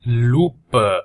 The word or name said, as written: lupa